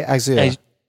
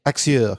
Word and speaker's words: Axur: I say Axur. Axur. – Yeah, it sounds like the Azure, the Microsoft Azure.